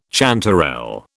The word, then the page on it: chanterelle: British English